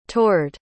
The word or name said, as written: toured